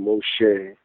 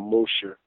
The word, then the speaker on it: Moshe: We were black Jews, you know, and that's how I grew up, and that's how I got the name Moshe or Moshe. It doesn't matter how the E is pronounced, it's okay, but my father gave me the name Moshe when I was...